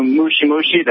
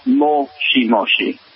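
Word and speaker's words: mochi moshi (もしもし): Like when you said, you knew mushi mushi, that's actually incorrect pronunciation, for hello over the phone. It's mochi moshi. – Mochi moshi. – Yes. Mushi means bug. You essentially said bug bug, but, moshi, and that's really just listening very carefully to their pronunciation.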